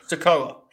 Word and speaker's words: Sikoa: Sikoa. Is that the same? I wondered if there was like an extravagant pronunciation. Just literally Sikoa.